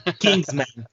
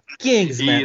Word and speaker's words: Kingsman: Kingsman. Kingsman.